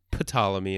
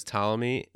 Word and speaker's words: Ptolemy: The two had been close friends since Alexander was young, and apparently, his name was probably pronounced Ptolemy in ancient Greek, but it's come down to us as Ptolemy, so I'll stick with that.